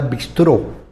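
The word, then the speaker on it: bistrot: Los estudiosos de la etimología nos dicen que la palabra bistró, recuerden que la T final no se pronuncia,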